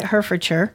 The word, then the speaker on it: Hertfordshire: I think that's how you pronounce it, Hertfordshire.